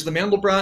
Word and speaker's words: Mandelbrot: is there any different aspects to it that makes it stand out from a Mandelbrot, or am I pronouncing that right? Mandelbrot. – There's the Mandelbrot, and then there's multibrots, where you change the formula.